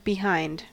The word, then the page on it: behind: American English